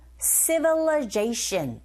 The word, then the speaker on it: civilization: 그리고 civil-i가 아니라 이때 발음은 강세 앞부분에 이 부분에 있어요. 뒤에 나온 i 부분에 강세가 없기 때문에 이 소리 자체가 약화되어져서 어처럼 발음이 들립니다. 그래서 civil, civilization 이렇게 발음하셔야 돼요.